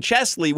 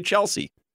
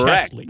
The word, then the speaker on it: Chesley: And you pronounced his first name Chesley, which is great. Most people say Chelsea. – Well, there's an S before the L. So correct. – It's Chesley.